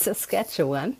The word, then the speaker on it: Saskatchewan: Also ähm, der heißt Saskatchewan.